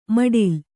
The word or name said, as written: ಮೊಟಲೆ